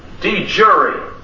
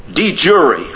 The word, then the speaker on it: de jure: It's pronounced de jure. And it means something that is true in law. De jure. I looked up Tate again in 1980 Oxford. And again, it's de jure. They don't give anything else for it. It's de jure. That's the pronunciation.